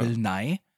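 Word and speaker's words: Bill Nighy: Der N-I-G-H-Y geschrieben wird. Falls man sich fragt, wie es ausgesprochen wird, Bill Nye.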